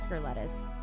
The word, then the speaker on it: Scurletis: How do you pronounce it? – Scurletis. – Scurletis. Okay. – Yeah, it's like that. I think that's what I thought in my head. But no one can ever pronounce it. They're like, Scurletis? I'm like, oh, it sounds like a disease.